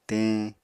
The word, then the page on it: teñ: Apatani